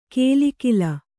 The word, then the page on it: ಕೇಲಿಕಿಲ: kēli kila